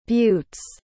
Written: buttes

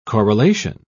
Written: correlation

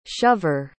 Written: shover

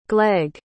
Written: gleg

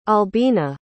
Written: albina

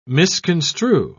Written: misconstrue

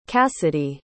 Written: cassidy

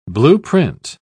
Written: blueprint

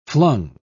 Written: flung

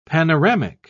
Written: panoramic

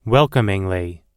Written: welcomingly

